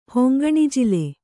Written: ಹೊಂಗಣಜಿಲು

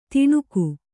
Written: ತಣುಕು

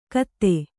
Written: ಖತ

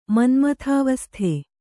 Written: ಮನ್ಮಥಾವಸ್ಥೆ

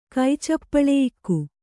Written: ಕೈಚಪ್ಪಳೆಯಿಕ್ಕು

